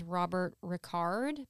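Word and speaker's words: Robert Ri'chard: I believe you pronounce this name as Robert Ricard because it's spelled like Richard, but it has like an apostrophe between the I and the C. So that sounds right.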